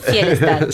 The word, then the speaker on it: Fjellstad: Fjellstad.